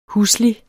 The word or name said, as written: huslig